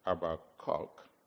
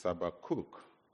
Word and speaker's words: Habakkuk: And he would not like you to call it Habakkuk, because it's not a cock. It's Habakkuk, because it's a cook.